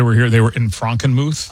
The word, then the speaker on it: Frankenmuth: But there is a German town, a little Bavarian place called Frankenmuth. That is the exact pronunciation.